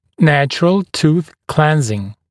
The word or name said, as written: natural tooth cleansing